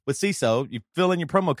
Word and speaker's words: Seeso: So let me tell you, CISO, it's spelled S-E-E-S-O, and it's an ad-free streaming service bringing you hilarious shows, hilarious comedy, and original series.